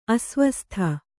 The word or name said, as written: ಅಸ್ವಸ್ಥ